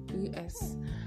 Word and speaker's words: mischievous: Guess, because I've heard lots of people pronounce this word as mischievous, placing the stress on the second syllable, which is wrong. Guess what? The correct pronunciation of the word is mischievous. Mischievous. The stress is on the first syllable, that is miss.